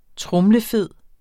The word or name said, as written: tromlefed